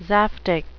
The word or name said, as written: zaftig